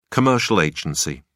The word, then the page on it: commercial agency: British English